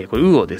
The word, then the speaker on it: UUUO: ウーオ、はい、これウーオですね、 2020 年 9 月に、鮮魚取引のプラットフォームウーオ、 UUUO と書いてウーオなんですけども、を開始しました。